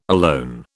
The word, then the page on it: alone: British English